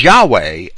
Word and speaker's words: Yahweh: We can also be confident that the name should be pronounced as Yahweh, at least approximately, because of the manner in which it was described by Flavius Josephus and others in early times.